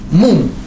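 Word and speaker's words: Мум (מאום): Мум. Читается также. Мем, алеф, вав, мем. Алеф этот не читается, но тем не менее пишется.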